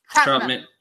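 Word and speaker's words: Trautman: Is that how you spell it? Troutman? – Troutman. Yes. – Troutman. – Pretty close. Troutman.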